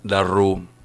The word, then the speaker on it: Darrou: Bueno, señor Matías Darrú, está bien dicho.